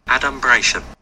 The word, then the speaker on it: adumbration: So it's adubration? – Let's hear. – Adumbration.